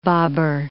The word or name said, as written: bobber